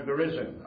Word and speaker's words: Gerizim: Jerusalem or Gerizim, probably the correct pronunciation.